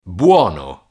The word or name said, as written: buono